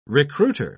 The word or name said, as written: recruiter